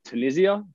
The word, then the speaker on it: Tunisia: The man that won the 400 freestyle, 18-year-old from lane eight from Tuznia. Is that how you say it? Tuznia? – I like to go with Tunisia, but if you want to do Tuznia, you can do Tuznia. It's Tunisia, mate. Come on.